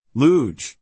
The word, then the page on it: Bydgoszcz: \ˈbid-ˌgȯsh(ch)\; BID-goh-sh-ch; Poland